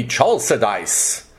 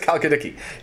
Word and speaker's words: Chalkidiki: You would think it would be Chalcedice, but apparently it's Chalkidiki.